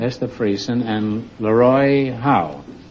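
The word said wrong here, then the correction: Hough: No, that's Huff, Groucho. H-O-U-G-H. – How can it be Huff? – Well, B-O-U-G-H is bow, isn't it?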